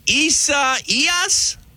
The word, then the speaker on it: Isaias: Hurricane Isaias? – That's pretty good. Oh, I'd say a little more Y in the middle. So Isaias. – Isaias. Okay. – Isaias. There you go. – E-I-E-I-O, kind of an Isaias.